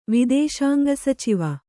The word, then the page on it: ವಿದೇಶಾಂಗಸಚಿವ: kannada